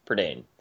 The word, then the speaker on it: Prydain: Philip has Heinlein's Juveniles, Anne McCaffrey's Pern series, Lloyd Alexander's Chronicles of Prydain? – Prydain.